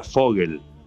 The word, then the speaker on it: Vogel: Y mirá cómo son las cosas que después nos enteramos que Vogel es en alemán pájaro porque se pronuncia Vogel. La B corta se convierte en F.